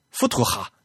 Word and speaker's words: Futura: 是 Futura，富图哈，是德语应该念富图富图哈。